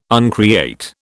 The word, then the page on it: uncreate: British English